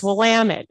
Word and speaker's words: Willamette: It's Willamette. It rhymes with damn it.